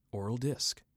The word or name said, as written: Oral Disk